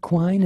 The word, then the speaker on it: Quine: You've had some amazing guitarists, Richard Lloyd and I want to say Robert Quine.